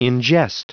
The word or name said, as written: ingest